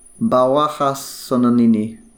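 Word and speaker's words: Bahuaja-Sonene: It's pronounced Bahuaja Sonini National Park. Sonine.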